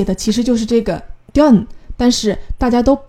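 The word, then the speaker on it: john (뎐): 但是当你看到文字的时候，可能会有一个小小的疑问：这最后一个字是 “dun”，它可不是 “john”。那么为什么他们在念剧名的时候都是把它念成 “john” 这个字呢？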